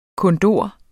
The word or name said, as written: kondor